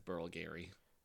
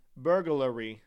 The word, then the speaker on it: burglary: Burglary. Because there's no A between the G and the L. It's burglary.